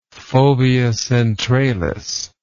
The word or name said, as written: fovea centralis